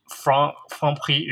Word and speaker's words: Franprix: I mean, with most French words, you don't pronounce the last letter. – Mm-hmm. – So like some of the last letters like X, for example. – Yeah. – So actually Franprix is the way to pronounce it.